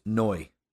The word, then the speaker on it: now: For example, the word now is more often pronounced noi, like spell almost like N-O-Y.